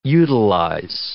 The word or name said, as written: utilize